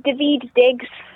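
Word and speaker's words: Daveed Diggs: Anybody who's into the rap music, check out Davy Diggs. Is that how you say it? – It's David Diggs.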